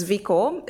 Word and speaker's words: Zviko: So my name is Zviko. It's spelled Z-V-I-K-O.